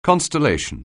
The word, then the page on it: constellation: anglais